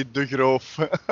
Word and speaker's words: De Groof: That's how English people pronounce it, but it's actually De Groof. – it's De Groof. – But I'm already used to it that people call it De Groof.